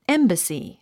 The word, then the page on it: embassy: émbəsi; エンバスィ